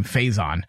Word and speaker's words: Faison: Hype played by Donald Faison, I believe is how you say his name, Faison.